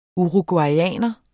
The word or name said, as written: uruguayer